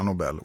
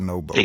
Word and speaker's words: Nobel: Det är ju en herre som heter Nobel, man borde säga Nobel och inte Nobel.